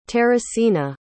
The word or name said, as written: teresina